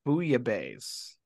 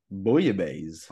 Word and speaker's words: bouillabaisse: I-S-S-E. – All righty. Let's take a look here. Here we go. It is pronounced Bouillabaisse. – Bouillabaisse. – Oh, here we go now. – That sounds a lot fancier than what I was saying.